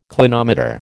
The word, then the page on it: clinometer: American English